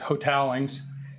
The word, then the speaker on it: Hotaling's: And Hotalings, or as the family pronounce it, Hotalings. – Hotalings. I'm even way off. It didn't even correct me. I was saying Hotalings. I'm like not even in the same ballpark here.